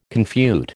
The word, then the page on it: confute: American English